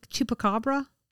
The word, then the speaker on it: chupacabra: Chupacabra.